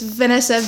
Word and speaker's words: Vanessa: Like, my name is Vanessa, and if I had a dollar for every time someone spelled it wrong, I'd be a millionaire to this point. Because it's Vanessa, V-A-N-E-S-S-A. People would spell it as V-E-N-A-S-S-A.